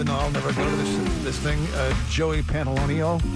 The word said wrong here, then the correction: Pantoliano: Am I saying his name right? I'm not saying his name properly, am I? Joey Pants, you know this guy? Joe Pantoliano, thank you.